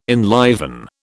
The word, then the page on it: enliven: British English